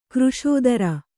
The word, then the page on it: ಕೃಶೋದರ: křśodara